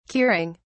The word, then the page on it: curing: British English